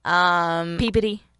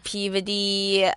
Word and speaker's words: Peabody: Peabody. – Peabody.